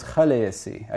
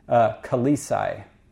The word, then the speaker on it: Khaleesi: So you have this word spelled like this, and so I said if it's spelled that way, it should be pronounced different. And so the proper pronunciation is Khaleesi. I should have realized that nobody was ever going to pronounce it that way. And I should have respelled it. Oh, well. Incidentally, you know why this is spelled this way? Because George R. R. Martin pronounces it Khaleesi.